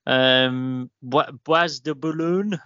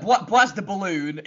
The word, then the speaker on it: Bois de Boulogne: Bois de Boulogne? I don't know. – Bois de Boulogne. It started off okay. It ended terribly. It was the Bois de Boulogne.